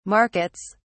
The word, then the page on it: markets: British English